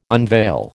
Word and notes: unveil: American English

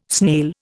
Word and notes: snail: Indian

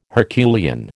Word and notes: Herculean: American English

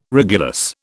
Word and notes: regulus: British English

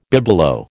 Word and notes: bibelot: American English

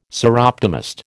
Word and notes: Soroptimist: American English

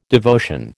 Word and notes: devotion: American English

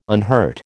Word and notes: unhurt: American English

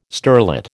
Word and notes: sterlet: American English

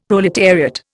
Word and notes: proletariat: Indian

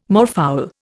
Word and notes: moorfowl: Indian

